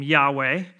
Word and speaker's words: Yahweh: You've probably heard this before, that that comes from his name, Yahweh, and that's kind of how you would pronounce it if you were reading it directly.